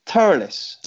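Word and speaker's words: Thurles: Turles. That's the correct pronunciation, DJ, isn't it? – Yeah, T-U-R-L-A-S. That's how it's pronounced.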